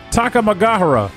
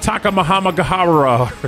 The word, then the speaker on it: Takamagahara: And Cameron says, this is actually pronounced Takamagahara. – Okay, there you go. So it's all together there. You knew it. You had the letters. You had the syllables in mind. – I had like one too many set of four letters thrown in there. I had like Takamahamagahara or something.